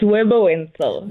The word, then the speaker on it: Webber Wentzel: Of course, being a candidate attorney at, uh, v- is it Webber Wentzel? Webber Wentzel, did I say that correctly? – It's Webber Wentzel.